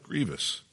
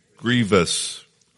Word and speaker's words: grievous: It says, "For this is the love of God, that we keep his commandments, and his commandments are not grievous." By the way, how do we say that word? Grievous. Grievous. Likewise, mischievous.